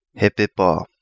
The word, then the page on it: Hepepa: Kapingamarangi